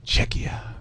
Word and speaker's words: Czechia: Oh, against who? – Czechia. That's how it's said on the website there.